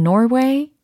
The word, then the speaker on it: Norway: N-O-R-W-A-Y 라는 철자이고요. 발음 한번 해보세요. 노르웨이는 영어로 네, 좋아요. 자, 이번엔 저의 발음을 한번 들어보세요. Norway, Norway 이겁니다. 아하, 왠지 콧소리가 굉장히 많이 나는 것 같아요. 그죠? 그리고 노르웨이에서 그 르가 사라지는 느낌입니다. 그래서 Norway, Norway Norway 하고 계시죠?